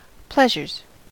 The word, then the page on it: pleasures: American English